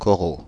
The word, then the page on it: coraux: Paris: IPA: [kɔ.ʁo]